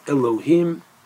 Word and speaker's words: Elohim: Now, it's pronounced Elohim.